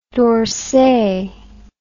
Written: dorsay

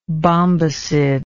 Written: bombycid

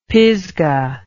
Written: Pisgah

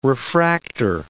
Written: refractor

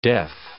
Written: def

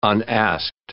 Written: unasked

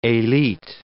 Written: elite

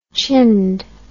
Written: chinned

